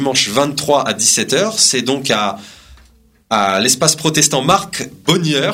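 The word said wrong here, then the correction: Boegner: je sais pas si je prononce bien. – Je crois que c'est Bogner. – Bogner.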